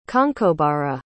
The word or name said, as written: conchobarra